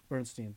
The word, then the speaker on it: Bernstein: Stein? How do you say it? – Bernstein, thank you.